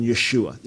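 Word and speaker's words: Yeshua (ישוע): The original pronunciation of Jesus' name would have been Yeshua. There's an I in it at the end. It would have been an ah sound, but Yeshua.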